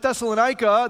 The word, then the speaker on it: Thessalonica: You can read about the establishment of the church at Thessalonica. That's the English way we pronounce it. That city is still there today. It's called Thessaloniki. It's in northern Greece.